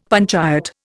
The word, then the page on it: panchayat: Indian